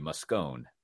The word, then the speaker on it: Moscone: I'm staying, it's at the Moscone. Or I think if you were to pronounce that in Italian, it'd be Moscone.